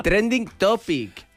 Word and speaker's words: Trending Topic: ¿Cómo se dice? ¿Tetin Tropic? – Trending Topic.